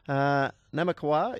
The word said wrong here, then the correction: Namakwa: is the favourite. Or Namakawa. How do we pronounce the favourite here, Warren? I always get this one wrong. – Namakwa, that's it.